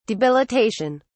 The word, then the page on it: debilitation: British English